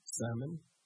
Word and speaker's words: salmon: I'd probably put like a protein in there, some salmon, not pronouncing the L, maybe some chicken, right?